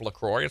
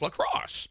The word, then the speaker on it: LaCroix: It's not pronounced LaCroix. It's LaCrosse. Yeah. And I said, well, I'm first of all, I understand why you might hear that. As I said, there are a ton of influencers on TikTok and elsewhere who have videos of saying, it is not pronounced LaCroix. It is LaCrosse.